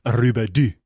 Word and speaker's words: Robidoux: It's spelled R-O-B-I-D-O-U-X, Rubidoux.